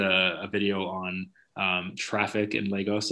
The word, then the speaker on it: Lagos: Lagos, yeah.